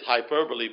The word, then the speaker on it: hyperbole: H-Y-P-E-R-B-O-L-E with the E at the at the end pronounced like it's a double E, hyperbole.